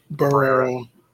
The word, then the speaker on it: Barrera: Barrera.